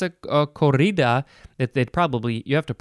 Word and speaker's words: corrida: Next is corrida. It is spelled, well, an English person, an American person would say corrida, but it is a Spanish word, so it is corrida. C-O-R-R-I-D-A.